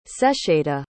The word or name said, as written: Sesheta